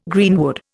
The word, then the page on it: greenwood: Indian